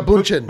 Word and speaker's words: Bündchen: Giselle Bündchen.